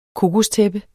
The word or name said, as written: kokostæppe